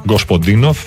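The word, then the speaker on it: Γκοσποντίνοφ (Господинов): Η δική μου μετάφραση σε όλα αυτά, έτσι στα γρήγορα τώρα πριν κλείσουμε την εκπομπή, γράφει ο Γεώργη Γκοσποντίνοφ, αν το προφέρω και σωστά το όνομά του, ναι, Γκοσποντίνοφ,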